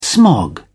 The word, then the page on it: smog: British English